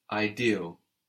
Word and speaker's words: idéal: Good, so we have I like in ink, E like in elephant, ideal. How would you say it's not ideal?